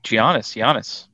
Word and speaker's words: Giannis: is definitely Giannis. Sorry, almost did it again. – You did it again. There's no almost. You did. – No, I did. That was a silent G.